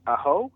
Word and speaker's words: Aho: How do you say his name? – Aho. – Aho. – Yeah, you've you've put bet, the you've I put the wrong you've put the wrong emphasis on the wrong syllable. It's it's yeah. aho. Aho.